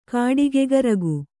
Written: ಕಾಡಿಗ್ಗರಗೆ

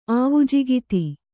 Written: ಆವುಜಿಗಿತಿ

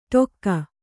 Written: ಟೊಕ್ಕ

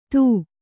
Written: ಥೂ

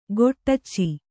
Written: ಖಡಚಿ

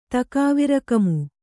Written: ತಕಾವಿರಕಮು

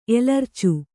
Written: ಎಲರ್ಚು

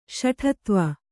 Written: ಶಠತ್ವ

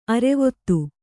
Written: ಅರ್ವತಿ

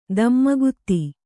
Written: ಧೂಮಕೇತು

